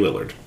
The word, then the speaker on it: Lillard: Lillard. – Ooh, we said it correctly. – Matthew Lillard.